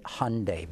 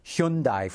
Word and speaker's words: Hyundai: So anyway, I know my friends at Hyundai keep gently reminding me to pronounce it Hyundai. But I got to tell you, after saying it Hyundai for 25 years, it just doesn't come natural to me.